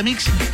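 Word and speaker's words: MX: Así mismo, se pronuncia Mix, que le suene esa X, pues ahí al final, bien bonita, bien hermosa.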